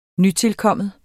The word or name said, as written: nytilkommen